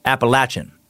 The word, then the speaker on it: Appalachian: And there, yes, you will hear Appalachian. Would have never known that was the local way.